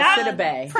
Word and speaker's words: Sidibe: Sabidi? Gets her own sex scene on the most recent episode. – Oh, Gabrielle Sidibe.